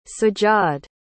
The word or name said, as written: sajjad